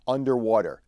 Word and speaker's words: UNDW3: It's called the UNDW3 card, and I believe that UNDW3 is pronounced underwater, the underwater card.